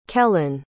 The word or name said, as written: kellyn